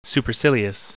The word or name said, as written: supercilious